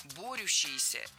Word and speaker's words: борющиеся: Борящиеся неверно, причем в этом слове сразу две ошибки. Во-первых, ударение должно падать на первый слог. Во-вторых, в этом слове суффикс «ющ», а не «ящ». Борющиеся – это правильный вариант.